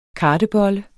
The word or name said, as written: kartebolle